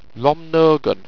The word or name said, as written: romnorgan